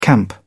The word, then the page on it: camp out: British English